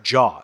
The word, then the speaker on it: Zhao: Chloe, it looks like Zhao, Z-H-A-O. It's actually pronounced Jaw.